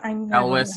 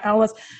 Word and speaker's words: Elwes: Cary Elwes, however you say his name. – Elwes, yeah. – Yeah, Elwes. That is also hilarious that he's like in this movie.